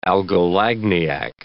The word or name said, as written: algolagniac